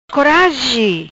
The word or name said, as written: Corazi